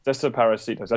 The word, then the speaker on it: Desaparecidos: I was nervous about trying to pronounce the name. I was going to say it, and that was Desaparitos, but I don't know if that's how you say it. – Desaparecidos. – Desaparecidos. I should just copied you.